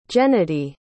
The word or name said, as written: gennadiy